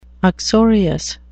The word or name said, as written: uxorious